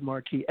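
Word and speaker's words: Marquis: Everything's under the same name, BRADDMARQUIS, Brad Markey. S is silent.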